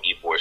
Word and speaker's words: Ybor: Is it Ybor City Phantom? That's right, isn't it? Did I pronounce that correctly? – It's Ybor.